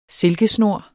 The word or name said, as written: silkesnor